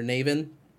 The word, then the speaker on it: Navin: He's like, I think it's because it's innocent and you're getting on the side of Navis right away, or Navin. God, I keep saying his name wrong.